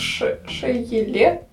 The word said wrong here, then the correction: Шееле: Шееле? – Ше, Специально себе поставила ударение, все равно ошиблась.